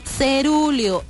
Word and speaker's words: cerúleo: lo estaba pronunciando mal porque lo estaba diciendo con I, no es con I, es con E. Cerulio.